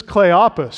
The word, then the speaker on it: Cleopas: This is Cleopas, short O sound, omicron used there, shortened form of Cleopatras, meaning illustrious father, glorious father.